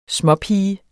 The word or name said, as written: småpiger